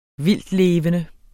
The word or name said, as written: vildtlevende